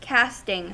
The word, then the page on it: casting: American English